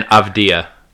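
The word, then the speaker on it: Avdija: Denny Avidici. No, Avidia. I don't know. – You're right, so Denny. Yeah, D-E-N-I, Denny. And then Avdia.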